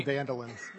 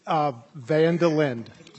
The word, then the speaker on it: Vandelindel: It's Aaron Vandelindel. – How would you like me? I keep thinking of Seinfeld and Vandelay Industries, so you got to give me the right pronunciation. – It's Vandelindel.